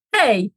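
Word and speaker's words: hej: Pero la versión fácil de esta palabra, hey, jak się masz? – Hey, jak się masz? – Sí, sí, hey, con H, la pronuncias, tienes que pronunciar la H. Hey.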